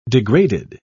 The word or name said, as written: degraded